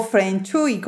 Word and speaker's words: Feng Shui: Sí, asesora profesional de Feng Shui. Ah, perfecto. O Feng Shui, como ella ahorita me estaba enseñando cómo se pronunciaba.